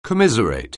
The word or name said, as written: commiserate